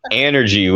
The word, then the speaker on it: Anergy: Anergy with a short A.